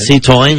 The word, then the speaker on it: Citroën: La no, pronunciación. – Citroën. – Citroën. – Sí, muy bien.